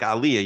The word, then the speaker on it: aliyah: So what any of you, if you want to explain, tell me how to say it properly when you're making aliyah, and then You're saying it right. Aliyah.